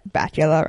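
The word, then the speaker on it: Bakula: Except for that Bacula rhymes with Dracula.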